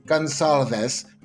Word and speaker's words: Gonçalves: I'd like to start off by correcting a name that I pronounced incorrectly in the last episode, as you noticed from the record scratch that our producer Leah Drummond brilliantly added in to let me know, oops, and gave you the correct pronunciation. So I'm going to say the name was Gonçalves, not whatever nonsense I said.